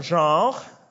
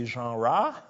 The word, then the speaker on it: genre: Number 11, genre. I didn't say genre. I say genre. That's the way it's pronounced in French.